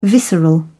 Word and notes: visceral: British English

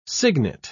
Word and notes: signet: British English

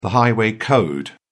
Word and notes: highway code: British English

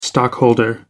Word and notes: stockholder: American English

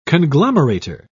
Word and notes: conglomerator: British English

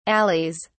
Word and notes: alleys: British English

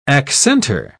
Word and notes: accentor: British English

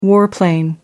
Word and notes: warplane: British English

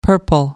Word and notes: purple: American English

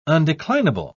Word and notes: undeclinable: British English

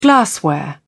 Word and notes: glassware: British English

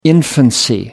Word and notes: infancy: American English

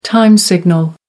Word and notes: time signal: American English

